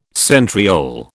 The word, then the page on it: centriole: British English